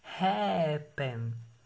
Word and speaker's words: happen: que é o caso de happen. Que se a gente for ver aí, ó, se a gente, igual a gente faz em português, pelo menos eu aprendi assim, para saber a sílaba tônica de uma palavra, que é só chamar como se estivesse chamando ela. Então, happen. Então, sílaba tônica é a primeira, ha.